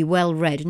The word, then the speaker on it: well read: And to have experience of a lot of books, to be well read. To be well read, not well read.